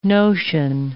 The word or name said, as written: notion